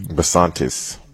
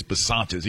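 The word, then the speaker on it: Bisontis: All right, they had also a lineman get injured, second-round guard Chase – how you say his name? – Besantis. – There you go, Chase Besantis.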